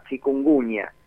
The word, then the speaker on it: chikungunya: Bueno, fundamentalmente dengue, y tenemos un invitado nuevo en nuestro menú sanitario que se llama chikungunya.